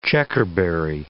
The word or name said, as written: checkerberry